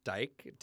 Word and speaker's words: Dyke: It is Dykemon, which sounds bad, but my last name is Dyke, D-Y-K-E. It is a Dutch name, and the original Dutch spelling of that is D-I-J-K, which is how Dykemon is spelled in my game as my account, D-I-J-K-M-O-N, and it's just Dykemon.